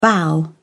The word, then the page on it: bow: British English